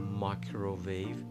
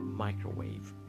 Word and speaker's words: مایکروویو: پس ماکروویو که فارسی شده یه ماکروویو هست، غلط هست، نادرسته، هم از لحاظ تلفظی هم از نظر فنی و معنایی. پس فقط یک تلفظ داره این واژه و اون هم مایکرو، مایکروویو، مایکروویو، مایکروویو.